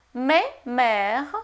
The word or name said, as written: mais — mère